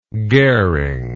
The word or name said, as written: goering